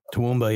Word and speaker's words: Toowoomba: How do you say that? Right? Then wasn't that? Isn't it Toowoomba? – Toowoomba, yeah. – Yeah. Oh, I had it right.